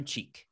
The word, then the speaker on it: Cheak: Is that how I say their name? – Aaron Cheek. Yeah. – Aaron Cheek. Okay. But it's spelled like C-H-E-A-K.